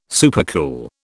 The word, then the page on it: supercool: British English